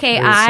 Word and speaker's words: Brekkie: It's spelled B-R-E-K-K-I-E. Brekkii.